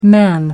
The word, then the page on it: man: American English